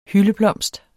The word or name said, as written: hyldeblomst